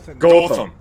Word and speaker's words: Gotham: Gotham.